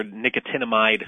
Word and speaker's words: nicotinamide: Nicotinic acid or nicotinamide.